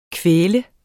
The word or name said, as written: kvaltes